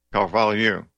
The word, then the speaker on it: Carvalho: Olavo de Carvalho? – Carvalho. – Yeah. Okay. Those hillbillies pronounce an H as an H, not a Y, but we don't know any better.